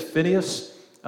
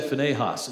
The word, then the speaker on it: Phinehas: And so this guy's name is, some people say it's Phinehas. My dad always said Phinehas, and so I like that pronunciation.